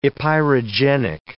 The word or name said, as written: epeirogenic